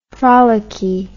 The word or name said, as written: frolicky